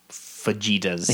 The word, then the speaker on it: fajitas: And good fajitas. – Fajitas. – Yes. – I think it's a soft J.